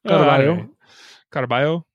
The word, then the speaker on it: Caraballo: Isn't two L's like a yah sound? – It's like a Y. Yeah. – Caraballo.